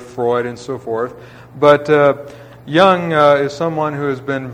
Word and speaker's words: Jung: That's, it's German, it's Austrian, it's J-U-N-G, but it's pronounced Jung as with a Y.